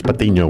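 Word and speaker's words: Patiño: Actually, if you want to say it right, it's Patino with an N.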